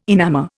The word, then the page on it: enamour: Indian